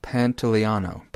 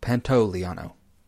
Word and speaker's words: Pantoliano: It's Joe Pantoliano. Pantoliano.